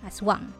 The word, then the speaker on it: Asuang: I completely forgot that we have a god named Aswang in Bicol, and it is spelled as A S U A N G, Aswang.